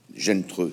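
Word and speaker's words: Zheng He: Gentreux, c'est ça. – C'est la prononciation. – Mais qui se lit Zen E. C'est pour ça que je n'arrivais pas à le prononcer.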